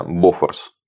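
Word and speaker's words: Bofors: У финнов основной, собственно, вид оружия, при помощи которого они боролись против нашей бронетехники, это шведское противотанковое орудие «Бофорс». Ну, иногда у нас говорят «Бофорс», там у шведов, как правило, на первый слог ударения.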